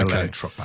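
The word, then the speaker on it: Atlantropa: and his scheme he called Allantropa. Have I pronounced that right? – That's right, okay. Allantropa. Yes.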